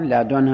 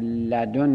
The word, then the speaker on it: لدن: لدن با ضم دال، لدن با فتح دال، لدن با کسر دال، این سه. با نونش دالش سه جور تلفظ میشه، آخرش نون باشه اون دالش با ضم فتح و کسر تلفظ میشه. لدن، لدن، لدن، و در هر سالت هم مبنی بر سکونه. و بدون نون هم تلفظ شده، بدون نون آخرش دال باشه، مبنی بر سکون، لد. وقت اونجا هم لامش سه جور تلفظ شده، با ضم، با فتح، با کسر، لد، لد، لد.